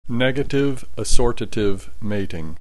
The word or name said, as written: negative assortative mating